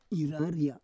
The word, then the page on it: iraria: Roon